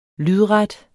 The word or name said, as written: lydret